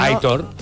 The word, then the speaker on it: Aitor: que dice, por favor, que Aitor se pronuncia como se lee. – Sí, no. Aitor. – Aitor dice la acentuación es en la O.